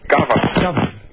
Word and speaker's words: Gawan: Wie spricht man den Namen aus? – Gavan. G-A-W-A-N.